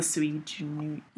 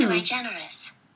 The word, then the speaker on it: sui generis: The sui generis. – And it's pronounced, thank you, phone. – Thank you. – Sui generis. – Sui generis.